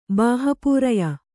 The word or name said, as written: ಬಾಹಪೂರಯ